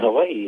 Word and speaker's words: Hawaii: So the context of Hawaii is, you know, as you, and I thank you for pronouncing it the way you've done, because, the breath that I just talked about.